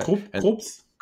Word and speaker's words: Krups: And I'm talking about like one of those little, I don't know how to pronounce it, Krups ones, right? – Krups. If they're German.